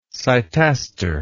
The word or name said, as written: cytaster